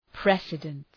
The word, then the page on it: precedence: ‘presıdəns